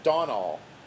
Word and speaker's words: Domhnall: We have our old friend, I'm going to mispronounce it again, but Domhnall yeah. – Gleeson. I heard it recently pronounced Donal, as though the M and the H aren't in there. So Donal Gleeson, I will start using that pronunciation.